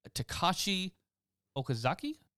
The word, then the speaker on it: Takashi Okazaki: The Ronin will return in a new comic series created by, I'm going to butcher this, Takashi Okazaki? – That was actually pretty good.